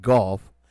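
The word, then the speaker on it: Gough: His name is pronounced Goff.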